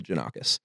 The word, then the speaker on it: Janakis: So the real pronunciation of it is Yannakopoulos. – Okay. – That's like the real Greek version. – Okay. – But when my great, great, great, maybe great grandfather came from Greece, they Americanized it to Janakis.